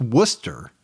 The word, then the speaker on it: Worcester: I believe it's pronounced Worcester. – Yeah. – Massachusetts. – I know where it's at. – Even though it's spelled more like Worcestershire sauce.